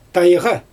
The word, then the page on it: tajɣa: Xyzyl